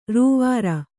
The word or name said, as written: ರೂವರಿ